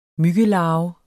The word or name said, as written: myggelarve